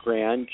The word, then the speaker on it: Gran: My first name is Steven, of course, and my last name is Gran, G-R, A as in apple, N as in nice.